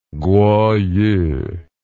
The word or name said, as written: kuoyu